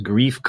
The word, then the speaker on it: Greef: His name in this episode or this show is called Grief Karga. – Yeah, it's a cool name. – It's spelled Greef. So not the usual grief, but not the Charlie Brown grief.